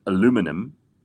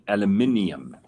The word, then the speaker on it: aluminium: So the Americans call it aluminum. – Which I don't understand. – No, and we call it aluminium.